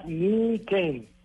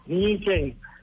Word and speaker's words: Niken: Eh, pues si nos vamos estrictamente al japonés sería nii-ken, como con un acento en, la E. – Ajá. – Niken, ¿sí? Porque realmente esa palabra eh, se descompone en dos palabras japonesas, Ni, que es el número dos en japonés, y Ken, que es espada estamos de dos espadas.